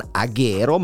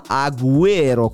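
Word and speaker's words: Agüero: Non Aguero, ma Aguero, come indicano quei due puntini sulla U.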